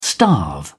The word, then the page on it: starve: British English